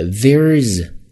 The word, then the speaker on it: There's: There's 在这儿的 th 首先是一个浊音咬舌头。 There's E-R-E 是 air 这样的一个双元音。